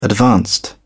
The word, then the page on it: advanced: British English